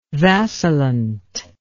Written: vacillant

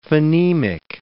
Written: phonemic

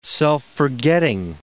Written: self-forgetting

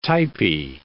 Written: typy

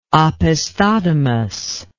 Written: opisthodomos

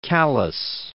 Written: Callas

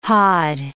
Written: hod

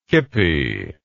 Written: kippy

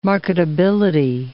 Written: marketability

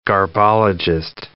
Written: garbologist

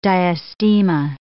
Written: diastema

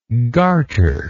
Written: garterless

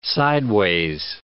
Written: sideways

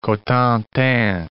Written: Cotentin Peninsula

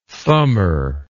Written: thumber

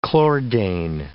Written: chlordane

